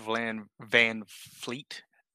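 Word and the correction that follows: VanVleet: How do you pronounce it? – It's just Van Vliet.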